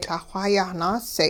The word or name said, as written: Lah-xw hayah naase'